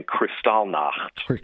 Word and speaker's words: Kristallnacht: So tell us about the 80th anniversary of the, correct me on the pronunciation here, is it Kristallnacht? Kristallnacht? – That's close enough. The fan, if we were on public radio, I would insist on saying Kristallnacht, which I think is how it's pronounced in German.